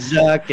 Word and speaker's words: Zuck: Out of all the billionaires, Zuck is like the funniest one. – Zuck's pretty good, right? – Yeah. – He rhymes with Suck, right? – Yeah.